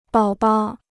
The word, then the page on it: 宝宝: bǎo bao